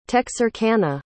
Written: texarkana

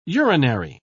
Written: urinary

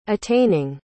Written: attaining

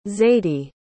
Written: zadie

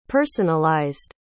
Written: personalized

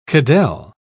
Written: cadelle

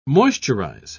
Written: moisturize

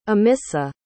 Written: amissa

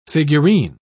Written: figurine

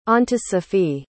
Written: ontosophy

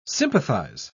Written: sympathize

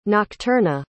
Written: nocturna

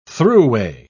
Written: throughway

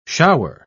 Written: shower

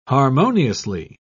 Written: harmoniously

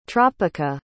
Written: tropica

